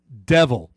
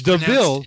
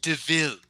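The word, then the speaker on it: De Vil: Your name is Cruella Devil. – It's Deville. Pronounced Deville.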